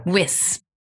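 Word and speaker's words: Wyss: Yeah. Rhymes with kiss. – Yeah. – Wiss.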